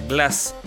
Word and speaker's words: Glac: La, banda esta de jazz de Rafael Lechowski, su nombre se escribe G-L-A-C. Pero en una entrevista que leí hace poco, escuché que se pronuncia Glass.